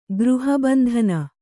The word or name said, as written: ಗೃಹಬಂಧನ